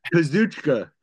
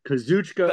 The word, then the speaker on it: Kazuchika: We got Bryan Danielson and Kasakata Okada, K- K- Kachikita Okada. I know like, Okada. – Kazuchika. Kazuchika. – But thank Okada. you. I, I couldn't pronounce it, but I know Okada's right.